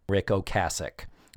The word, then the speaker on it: Ocasek: The other thing is, I mispronounced Rick Ocasek's name, the lead singer for The Cars, Rick Ocasek. That is how he pronounces his name, and that is how I should have pronounced it, but I didn't.